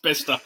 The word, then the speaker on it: Bicester: That's pronounced Bister.